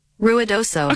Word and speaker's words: Ruidoso: Ruidoso.